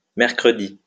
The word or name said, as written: mercredi